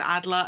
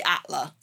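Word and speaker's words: Alder: But a game with such a queer focus as Nosferatu 2, and I forget how to pronounce the writer's surname because I always say it as Adler and Adler is wrong. Avery Atler.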